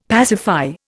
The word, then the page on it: pacify: Indian